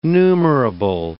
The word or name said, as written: numerable